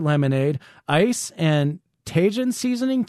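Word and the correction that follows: Tajín: I think it's pronounced tahine.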